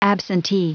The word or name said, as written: absentee